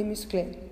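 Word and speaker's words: musclés: Por ejemplo, Instagram y Muscle. Ellos son altos y musculosos. Lleva una S al final, en Muscle, pero no se pronuncia.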